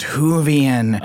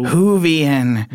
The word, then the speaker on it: Whovian: Do you know what he was talking about a few minutes ago when he just kept saying it's pronounced Whovian? Oh, Whovian.